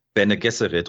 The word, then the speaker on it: Bene Gesserit: Bene Gesserit. – Bene Gesserit.